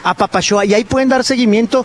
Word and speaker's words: Apapaxoa: Apapachoa se escribe con X, se escribe apapaxoa, pero se pronuncia apapachoa.